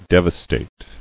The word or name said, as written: Devastator